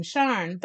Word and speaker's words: Sian: My choice for an astronaut is Sian, S-I-A-N, in honor of Sian Haley Proctor. The pronunciation is often Sharn, but not for Dr. Proctor.